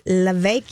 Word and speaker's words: Levesque: We've got David Levesque here, rhymes with cake.